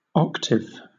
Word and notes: octave: British English

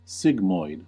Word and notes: sigmoid: American English

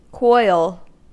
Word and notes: coil: American English

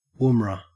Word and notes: woomera: Australian English

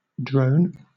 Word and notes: drone: British English

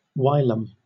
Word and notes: whilom: British English

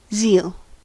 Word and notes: zeal: American English

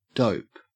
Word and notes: dope: Australian English

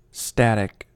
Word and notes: static: American English

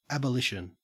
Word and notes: abolition: Australian English

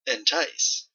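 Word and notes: entice: Canadian

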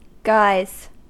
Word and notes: guise: American English

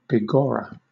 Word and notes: begorra: British English

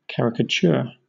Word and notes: caricature: British English

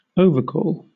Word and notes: overcall: British English

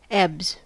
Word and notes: ebbs: American English

